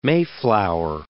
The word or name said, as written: mayflower